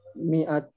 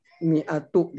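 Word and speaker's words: mi'at (مائة): Tapi pada uh, kata mi'ah itu unik. Setelah mim adalah huruf alif tetapi mimnya tidak dibaca fathah tapi dibacanya kasroh mi'at mi'atu gitu kan ya begitu.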